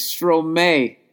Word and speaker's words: Stromae: Check out an artist named Stromayor. That's how you say it, Stromayor. No. My God, I'm an idiot. Oh, my God, I'm a fucking idiot. It's Stromae.